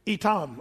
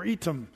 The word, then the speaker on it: Etham: Etam or Etam. Yeah, spelled like Etham.